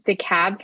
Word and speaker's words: DeKalb: Is it, do you pronounce it DeKalb County? – Mm-hmm. Yes.